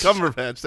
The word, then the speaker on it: Cumberbatch: I don't know how to not that way with a lack of confidence towards the end. Cumberbatch. – Cumberbatch, there it is.